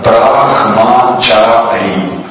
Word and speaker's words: Брахмачари: Хорошо, я слышу некоторые нюансы в произношении. Это все нормально, мы только привыкаем к этим словам. Я могу повторить, вы можете поправить, если кто-то писал конспект. Это первый ашрам называется Брахмачари. Но в санскрите ха, она не такая жесткая, то есть это не так там Брахмачари.